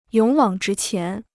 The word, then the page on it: 勇往直前: yǒng wǎng zhí qián